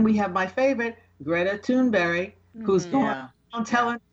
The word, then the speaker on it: Thunberg: But in Swedish, it's Thunberg. That's how it's pronounced.